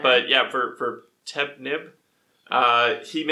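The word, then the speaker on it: Ptemenib: Also, the P's probably silent. – Yeah, the P-T is silent. Yeah, it's Tepnib. So Tepnib. So, sorry for mispronouncing.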